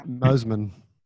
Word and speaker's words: Mosman: Pronounced Mosman. – Okay, sorry. Yeah, I did not know if that's how you pronounce it. But there you go. You know, basically this retirement village. – You said it right.